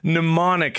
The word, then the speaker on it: mnemonic: Mnemonic has a silent M. M-E-N. M-N-E.